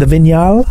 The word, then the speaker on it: Vignale: The Titanium, the ST line, and the Vignale, I think. The Vignale. So I was saying it wrong on holiday.